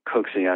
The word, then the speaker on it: Kuksi: So my name, Kuksi, as it's spelled, K-U-K-S-I, is augmented from my original spelling, which is C-O-O-K-S-E-Y.